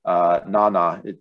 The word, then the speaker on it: Nyny: I highlighted Nana, even though it's spelled N-Y-N-Y, it's Nana Davis.